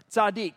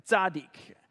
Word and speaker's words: tzaddik: It comes, or it's related to this Hebrew word, and the Hebrew word is, the Hebrew word, I should know this, tzaddik. Sorry, tzaddik. Can you say tzaddik? All right, if you were to spell that, it would go T-Z-A-D-D-I-Q.